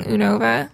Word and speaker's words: Unova: It was in Unova only, which I realize I've been pronouncing it wrong. I keep saying Unova, but it's Unova.